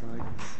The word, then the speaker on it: Igles: EI is like Eisenhower, so it's Igles. Yes.